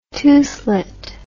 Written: toothlet

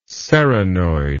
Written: serranoid

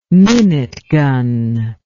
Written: minute gun

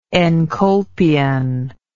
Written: encolpion

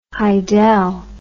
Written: hydel

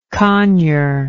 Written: conure

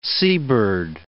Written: seabird

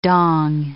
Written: dong

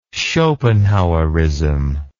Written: schopenhauerism

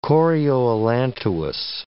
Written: chorioallantois

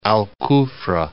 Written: Al Kufrah

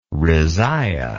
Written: rezaiyeh

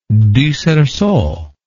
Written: du cerceau

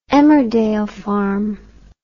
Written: Emmerdale Farm

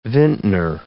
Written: vintner

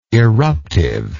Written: eruptiveness

